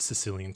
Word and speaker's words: Sicilia: all these different empires have affected and impacted Sicilian culture. – Their imprint. They've definitely imprinted. – And people from, and it's the way they pronounce it is Sicilia, but the way that they view themselves is they're Sicilian first and Italian second.